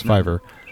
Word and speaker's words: Fiverr: I thought I mispronounced that, but no, that's actually how it's pronounced, Fiverr.